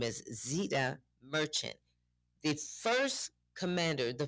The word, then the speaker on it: Zeita: Tune in tomorrow morning at 8 o'clock because I would like to talk to you about, I believe her, the way you pronounce her name is Zeita. It's Z-E-I-T-A Merchant.